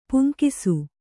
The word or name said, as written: ಪೆಂಗುಸು